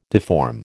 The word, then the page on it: deform: American English